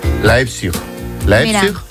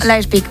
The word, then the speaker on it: Leipzig: ¿Lo he dicho bien? – Qué importante es saber idiomas. – Tengo aquí a mi amigo que me lo dice. ¿Cómo se dice? Leipzig. Leipzig. – ¿Ves? Leipzig, muy bien.